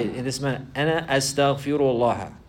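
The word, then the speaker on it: Astaghfirullah (أستغفر الله): Astaghfirullah. Astaghfirullah. He has to learn how to say it properly likewise. Some people, they say istaghfirullah, istaghfirullah. And they'll make the harakat wrong.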